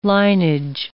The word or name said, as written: linage